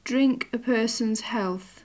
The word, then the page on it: drink a person's health: English